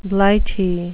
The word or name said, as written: blighty